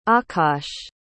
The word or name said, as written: akaash